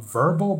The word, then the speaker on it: Vurbl: It's called um, Vurbl, but it's spelled like V U R B L.